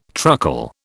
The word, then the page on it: truckle: British English